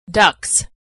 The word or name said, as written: ducts